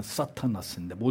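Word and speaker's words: 사타나스인데 (Σατανᾶς): 신약성경에서 사타나스, 우리가 말하는 사탄이라고 하는 단어, 원래의 발음은 사타나스인데 모두 36회나 나옵니다.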